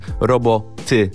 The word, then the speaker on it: Robo, Ty: Przed momentem Hades, Emadę, DJ Caps, Robo, Ty. To nie jest kwestia, że ja źle akcentuję, po prostu ty jako ja, a nie jako końcówka wyrazu roboty w tym tytule się znajduje.